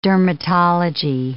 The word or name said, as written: dermatology